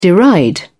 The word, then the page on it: deride: British English